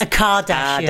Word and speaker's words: Kardashian: And I'm ready to rock and roll with this episode because we're going to be talking about the Kardashians. – Babe, don't act like you don't know who they are. They're billionaires. – Kardashian. I've said it right now, haven't I? – Kardashians. – A Kardashian.